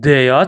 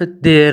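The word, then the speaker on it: der: دوستان، دوستان خیلی مهمه بدونید حرف در در زبان آلمانی دیا تلفظ میشه، دیا. خیلی مهمه که خود آلمانی، خب اکثرا دوستان در تلفظ میکنن، ولی باید بدونید که خود آلمانی ها دیا تلفظ میکنن.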